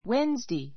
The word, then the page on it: Wednesday: wénzdei; ウェンズデイ